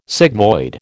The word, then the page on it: sigmoid: American English